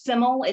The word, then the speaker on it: Syml: I believe it's pronounced Syml. It's S-Y-M-L.